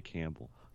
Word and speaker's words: Campbell: It's BJ Campbell.